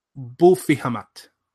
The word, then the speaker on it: Abu Fihamat: but could well be a corruption of the Arabic Abu Fihamat, pronounced in more Spanish, something like Bufihamat.